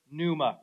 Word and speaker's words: pneuma: Everybody say the word pneuma, right? Pneuma. Yeah, it's kind of a fun word to say. It actually starts with a P. If you didn't know that, the P is silent, right? It's pneuma.